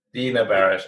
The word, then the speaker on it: Dana Barash: Have I said that right? – Dana Barash. – And sorry about that.